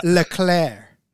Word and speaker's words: Leclerc: Did you listen to the pronunciation on how to say his name? Because Charles Leclerc. – That's what I thought.